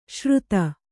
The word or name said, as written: ಶೃತ